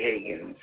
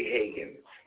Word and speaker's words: Hagins: If you're making your donation through Cash App, it's hashtag Dr. Ray Higgins, and my name is spelled H-A-G-I-N-S, not Higgins, H-I-G-G-I-N-S, okay? It's H-A-G, one G, not two Gs, Dr. Ray Higgins, all right?